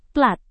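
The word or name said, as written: platform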